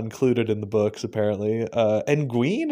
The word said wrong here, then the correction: Egwene: is that how you say her name? – Egwene. I always say Egwene. – I say Egwene. But Egwene.